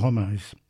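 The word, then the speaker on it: Holmès: In fact, she actually herself put an accent onto the last syllable in Holmes, so she called herself Augusta Mary Holmes, or Holmes.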